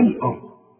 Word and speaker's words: الأرض: وإذا قيل لهم لا تفسدوا في الأرض، خد بالك الأرض دي احنا اتفقنا ان الهمزة بتاعت القرآن دائما مرققة، فمن قلش في في الارض، لا في الآه، في فرق اهو النطق، ما تقولش في الآه في ال، شوف حتى اللام طلعت منك مفخمة، لا اللام مرققة في الارض خلاص.